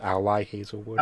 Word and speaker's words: Ali Hazelwood: Yeah, sorry, my real name is Ali Hazelwood. This is the love hypothesis. – No. – I think that's pronounced Ally Hazelwood.